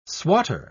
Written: swatter

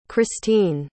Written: christene

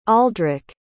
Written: aldrik

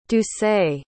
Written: doucet

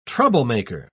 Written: troublemaker

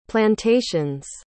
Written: plantations

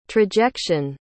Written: trajection

